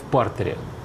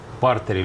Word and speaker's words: партере: Вот, все решается в партере или в партере. Ну, в партере в данном случае, как мы воспринимаем. – Есть такое, это такое ударение. Да. Я всегда думал, в партере, но вы как специалист в единоборствах, наверное, лучше знаете.